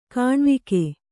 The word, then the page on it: ಕಣೆವೋಗು: kaṇevōgu